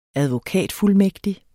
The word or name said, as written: advokatfuldmægtig